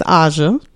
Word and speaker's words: Haja: H-A-J-A. – Ooh. I'm gonna say that the H is silent and go with Aja.